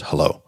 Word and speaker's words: Hullo: Um, it's Hullo Pillow, H U L L O, pronounced hello.